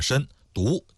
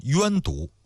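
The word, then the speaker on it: 身毒: 申就是身体的申，毒就是毒品的毒。关于这个读音呢，有好几种说法，有些书上包括央视的纪录片上把它读成是冤毒，但是还有一个专门的文章啊，就说央视里边这个冤毒呢是读错了，就直接读作申毒就可以了。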